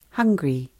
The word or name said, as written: hiakai